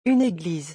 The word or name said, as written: une église